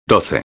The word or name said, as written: 12